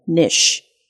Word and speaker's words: niche: 但是它其实有两种发音都可以，我们可以念 niche，也可以念 niche。